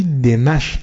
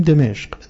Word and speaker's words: دمشق: تلفظ صحیح این کلمه در عربی دمشق است، منتها ما در پارسی میگیم دمشق، دمشق ما با فتح دان تلفظ میکنیم، ایرادی نداره، اصل کلام آن است و ما اینطور بکار.